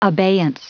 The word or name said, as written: abeyance